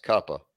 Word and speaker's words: Cooper: Well, and Arch's dad, Cuppa, you know, it's Cooper, but in the Manning family, it's pronounced Cuppa.